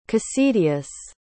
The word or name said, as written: cocidius